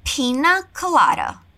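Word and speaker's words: piña colada: O nome não é em inglês, mas nós vamos repetir a pronúncia que é usada em inglês, ok? Pina colada.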